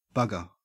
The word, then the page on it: bugger: Australian English